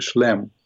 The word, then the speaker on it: Schlemm: Yeah, I called it Schliemann for whatever reason, but it's really, you pronounce it correctly, Schlemm.